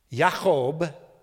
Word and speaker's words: Yaakov: And that was true to Jacob's name, because you'll remember that Jacob, Yaakov, as it would be pronounced in the Hebrew, means deceiver.